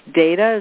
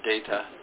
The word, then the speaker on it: Deida: If you want to check him out, so it's Data. Is that how it's pronounced? – That's how I pronounce it. – Yeah, David Data.